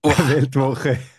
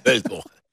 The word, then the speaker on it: Weltwoche: What's the right manner to pronounce Weltwoche? – I hate to say you are absolutely right. Weltwoche. – Weltwoche.